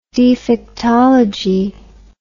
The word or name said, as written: defectology